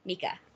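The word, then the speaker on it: Mieke: So the woman's name, by the way, is Mika, but it's M-I-E-K-E. But I nailed how to pronounce it. Mika.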